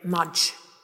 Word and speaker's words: Mudge: We call him Mudge, rhymes with fudge.